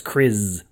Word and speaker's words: Kriz: Kriz. Sounds like Chris, but it's Kriz with a Z.